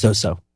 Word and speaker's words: XOXO: It's pronounced Zoso. – Oh, Zoso, like Jimmy Page.